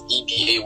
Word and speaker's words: Ebayy: So first things first, my surname is Ebayy, Ibe. But it's pronounced Ebayy still.